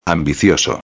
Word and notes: ambicioso: espanhol

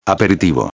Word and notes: aperitivo: espanhol

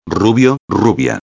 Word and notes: rubio-a: espanhol

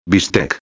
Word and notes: bistec: espanhol

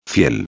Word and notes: fiel: espanhol

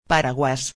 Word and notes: paraguas: espanhol

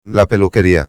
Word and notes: la peluqueria: espanhol

